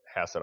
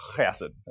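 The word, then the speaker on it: chesed (חֶסֶד): Now, the bishop was in a position to bestow one rabbi I was listening to said, if you say chesed, obviously you're butchering the word. You need to be spitting on the people in front of you to say it correctly. He's like, I'll try to avoid that, but it's not chesed, it's a chesed sound we don't really have in English.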